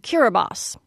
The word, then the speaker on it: Kiribati: There's a country in the Pacific called Kiribati, though it's spelled like Kiribati.